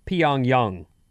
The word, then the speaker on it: Pyongyang: 408, please let Vinny know he's mispronouncing Pyongyang. – Pyongyang. – It's two syllables, and it's pronounced like Pyongyang.